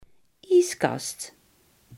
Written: koelkast

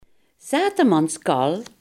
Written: dronkemanspraat

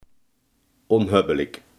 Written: onhebbelijk

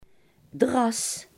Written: koffiedik

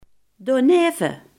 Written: daarnaast

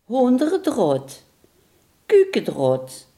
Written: kippegaas

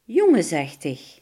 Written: jongensachtig

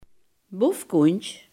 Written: bofkont

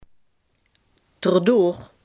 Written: erdoor

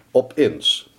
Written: opeens

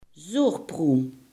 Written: zuurpruim

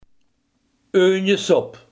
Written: uiensoep

